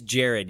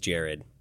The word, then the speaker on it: Jerod: So is it Jerod, Gerard? Help me out here. – It's Jerod. Yeah, I don't know why my parents felt the need. Well, I know they wanted to be sort of unique and spell it differently in honor of my uncle Rod, but it is Jerod.